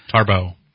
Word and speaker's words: Tarbox: One, they've hired a gentleman by the name of William Tarbox. – Tarbo. It's pronounced Tarbo. – Tarbo. Tarbo. Okay. – Like Tarbo. – Very French. – Silence. – Oh, very French.